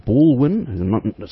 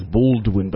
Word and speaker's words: Ballwin: Ballwin. Some people mispronounce it or misspell it as Baldwin, but this is there's no D in it.